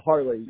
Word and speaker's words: Harley: It sounded like you said Harvey, but no, it's Harley, like the motorcycle.